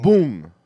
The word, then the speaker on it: Boong: uh, bong pronounced with the like like the vowel and bull Mm. Boong. Boong is how you say it.